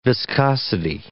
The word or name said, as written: viscosity